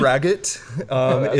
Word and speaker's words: braggot: So it is etymologically, it is a Welsh word, and I'll still butcher this, but it would originally have been something similar to Bragaud. But Braggot? Just Braggot is pretty good.